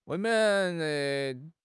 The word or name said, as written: 未免